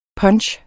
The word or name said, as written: punch